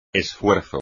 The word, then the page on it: esfuerzo: espagnol